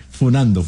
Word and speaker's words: funando: Exposición, exhibición, y ahora no se dice cascando, ahora se dice funeando. – ¿Qué usted? Pero eso yo tuve un mensaje de 48 años, por favor. – Sí, funando, me aclaran.